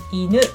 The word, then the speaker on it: inu: Okay, the pronunciation is the same, inu.